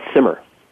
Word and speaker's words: Simar: It's Simar.